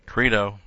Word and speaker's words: krino: In fact, the word krino, K-R-I-N, long O, krino, means to judge someone.